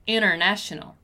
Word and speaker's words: international: Notice that when I said the word international a little faster, I didn't pronounce the letter T, right? I said international.